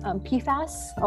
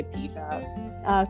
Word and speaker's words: PFAS: PFAS, or am I saying it correctly? – Yeah, that's how I say it. Some people say PFAS, but I think most people say PFAS.